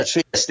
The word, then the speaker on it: Trieste: Yeah, Trieste.